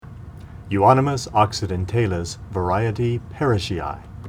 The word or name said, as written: Euonymus occidentalis var. parishii